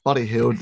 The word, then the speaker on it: Buddy Hield: Buddy Hield, is that correct? Am I pronouncing that correctly? – Yeah, we got Buddy Hield there.